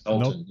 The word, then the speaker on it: Knowlton: Is it Knowlton? Is that pronounced? – Yes, Knowlton.